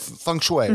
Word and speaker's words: feng shui: So I want to start right at what even got you interested in feng shui. Like what, and again, I'm saying that right? Feng shui, mm-hmm. Right? Okay. – Feng shui, you got it.